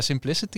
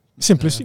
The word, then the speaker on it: Simpli-CT: Keep it simple inderdaad. Dus vandaar Simpli-CT, ja. – Simpli-CT, nou weten we hoe we dat moeten uitspreken, heel goed.